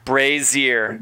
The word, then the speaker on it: brazier: Brazier. – Brazier. Thank you brazier. I need it like on the wall in real life. – Brazier. – Think Frasier, the terrible show Frasier.